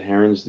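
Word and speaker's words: Herron: Is that how you pronounce his last name? Heron? – Yep.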